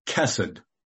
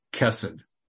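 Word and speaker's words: chesed (חֶסֶד): Well, loving kindness is a compound English word that's translated from a single Hebrew word that's pronounced chesed. It's spelled C-H-E-C-E-D, but it's pronounced chesed.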